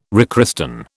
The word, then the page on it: rechristen: British English